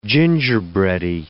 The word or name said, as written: gingerbready